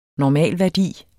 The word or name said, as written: normalværdi